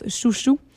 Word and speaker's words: Xiu Xiu: Et tout juste avant, au début du bloc, nous avions la formation expérimentale indie de la Californie du nom de Chouchou, mais qui s'écrit X-I-U-X-I-U, mais qu'on prononce Chouchou.